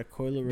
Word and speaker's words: Coi Leray: Thieves in Atlanta featuring Coi Leray. – Yeah, that shit was fire. Coil. Coil. Leray. – yeah, Ray. I said that right, right? – yeah, Coi Leray.